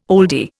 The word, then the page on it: oldie: Indian